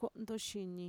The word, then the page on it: ko'ntoshini: Mixtec